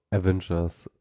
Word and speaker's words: Avengers: Avengers, wie wird das ausgesprochen? – Avengers.